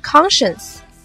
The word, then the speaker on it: conscience: 它经常和另一个词弄混，那就是 conscious, C-O-N-S-C-I-O-U-S, 形容词，神志清醒的。它们的读音也不相同，一个是 conscience, 一个是 conscious, 大家要区分清楚哦。我们再来学习一个单词 Pillow P-I-L-L-O-W